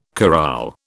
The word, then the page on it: corral: British English